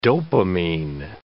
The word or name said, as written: dopamine